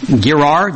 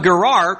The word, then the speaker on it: Gerar: If I ask you about the herdsmen of, I can't even pronounce it, Gerar, G-E-R-A-R.